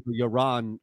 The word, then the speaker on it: Yaron: I think it's an Israeli name like mine. So okay. – Yaron would be the pronunciation. – Yaron Weitzman. – Y-A-R-O-N? – Correct.